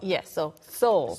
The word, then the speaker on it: Seoul: Seoul, S-O-U-L, 영어로 이제 영혼이지만 또 서울이라는 지명이 영어로는 또 Seoul처럼 발음이 됩니다.